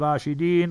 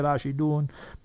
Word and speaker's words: Rashidun: all of them are part of the Khulafa'i Rashidin, Khulafa'i Rashidun, both ways you pronounce it.